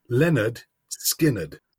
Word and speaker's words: Lynyrd Skynyrd: It's pronounced Lynyrd Skynyrd.